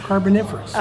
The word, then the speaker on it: Carboniferous: Am I saying that right? Carboniferous. – Carboniferous, okay, yeah.